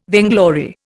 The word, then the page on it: vainglory: Indian